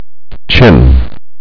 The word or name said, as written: chin